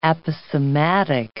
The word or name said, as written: aposematic